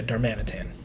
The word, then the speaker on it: Darmanitan: Darmanitan? That's how you say it? – Yeah, Darmanitan. Darmanitan is kind of a very rambunctious monkey mm-hmm.